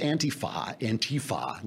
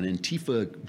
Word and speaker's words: Antifa: this thing with Antifa. Now we got to put the accent on the T. It's on the wrong syllable.